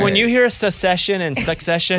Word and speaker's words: Succession: Secession. – Yeah. – Am I not saying it right? – I don't think so. – Succession.